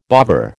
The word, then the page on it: bovver: American English